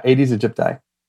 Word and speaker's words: Aedes aegypti: Yeah, Aedes aegypti.